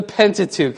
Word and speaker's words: Pentateuch: The Pentateuch. That's great. Where did you learn that from? Yeah. I was going to say it starts with penta and rhymes with ook, but you already got it, okay?